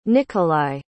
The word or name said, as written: nicholai